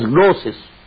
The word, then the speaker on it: gnosis: You spell it with G, gnosis, but it is pronounced as gnosis.